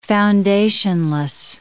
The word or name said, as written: foundationless